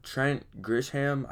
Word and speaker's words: Trent Grisham: Their outfield they got Trent Grisham. I think that's how you say it. Yes.